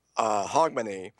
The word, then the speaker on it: Hogmanay: The Scottish called, they have a holiday the day after January 1st called, I think it's pronounced Hogmanay.